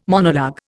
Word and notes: monologue: Indian

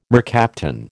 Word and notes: mercaptan: American English

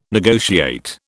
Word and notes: negotiate: British English

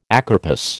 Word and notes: acarpous: American English

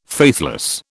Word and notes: faithless: British English

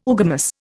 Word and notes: oogamous: Indian

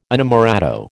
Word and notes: inamorato: American English

